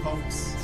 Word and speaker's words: Fawkes: Aquí incluimos algunos otros de la que estuvieron en la Torre de Londres: Sir Walter Raleigh, Guy Fawkes, ¿cómo se dice? Fawkes, ¿verdad? – Ah, sí, Fawkes.